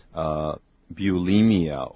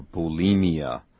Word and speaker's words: bulimia: Then in this case, we say that, you know, they get some sort of a disease, and that is, bulimia or bulimia. I better spell that. That's what the doctor calls it. B-U-L-I-M-I-A. Again, B-U-L-I-M-I-A.